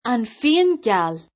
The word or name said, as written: fíon geal